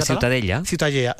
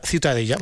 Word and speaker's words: Ciutadella: La Ciutadella. – Ciutadella.